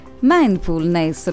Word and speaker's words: mindfulness: Oggi parliamo, questa settimana mi hanno messo la dura prova con i termini, spero questa volta di dirlo bene, mindfulness, l'ho detto bene dottoressa? – Sì sì.